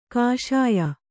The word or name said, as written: ಕಷಾಯ